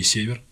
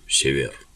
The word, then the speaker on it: Север: Потом знаменитый римский император Септимий Север, один из самых сильных римских императоров. – Извините, поправлю, Север.